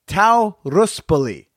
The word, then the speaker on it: Tao Ruspoli: This week, I have got on the director of the new documentary Monogamish, Tau Ruspoli, as I am told is pronounced.